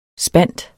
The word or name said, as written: spant